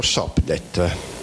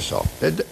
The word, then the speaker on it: Sopdet: Allora, e gli egizi la chiamavano Sopdet, anzi SPDT, poi si legge Sopdet, ed è questo, guardate, la stella e il cono, il cuneo in alto, perché viene indicato che sta sorgendo.